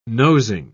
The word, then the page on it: nosing: British English